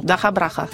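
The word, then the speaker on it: DakhaBrakha: Un groupe ukrainien, Dakabraka. Je prononce correctement? – Dakabraka. – Voilà, c'est beaucoup mieux comme ça.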